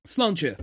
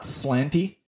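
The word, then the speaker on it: Sláinte: Slánce. – Okay. I thought I remembered how to say it properly. As long as it's not slanty.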